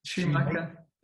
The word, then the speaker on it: Schumacher: Also, I'd just like to say how you said Shoemaker instead of Schumacher. Just wanted to throw that out there. – I said that intentionally because there's no C in it. It's Schumacher. – It's pronounced Schumacher.